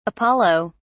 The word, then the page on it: apollo: British English